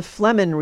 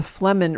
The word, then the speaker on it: Flehmen: Then there's something called the Flemmen response. It's spelled F-L-E-H-M-E-N, the Flemmen response.